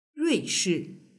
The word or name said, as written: ruishi